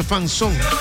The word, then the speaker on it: fanzone: Entonces envía la palabra fanzone, o sea, se escribe así, fan, F-A-N, fan, Z-O-N-E, fanzone, fanzón, se pronuncia fanzón.